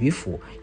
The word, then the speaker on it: 渔父: 事实上，这个渔父就是当时啊对隐逸的渔夫的一种尊称，我们应该读成渔父。啊，三声府一样是父亲的父这个字，可是古音啊，应该读成渔府，因为它主要的意思是这个啊，这个值得尊敬的隐逸之人，啊，值得尊敬的隐逸之人叫渔府。